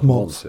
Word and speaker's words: Mogens: Eh, navnet Mogens ble gjerne uttalt skreven Mogens, men uttalt, uttalt Mons.